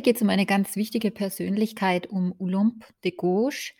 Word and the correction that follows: Gouges: De Gouche, so spricht man sie eigentlich aus.